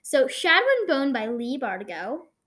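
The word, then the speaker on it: Leigh Bardugo: Leigh Bardigo. – Leigh Bardigo. Okay. I was right about the Bardigo part. – Yeah, I just was Leah, but just Leah.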